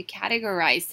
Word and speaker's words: categorize: Categorize 是分门别类的意思。我们可以说，It is easier to manage your documents if you categorize them.